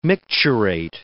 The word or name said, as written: micturate